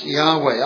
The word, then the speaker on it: Yahweh: And every time the word Lord is seen in this passage, it's the tetragrammaton, the four letters that stand for, that we pronounce Jehovah, or the Hebrews pronounce Yahweh.